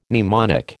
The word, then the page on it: mnemonic: American English